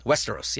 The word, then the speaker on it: Westeros: Okay, and this, is it pronounced Westeros? – Westeros, yeah.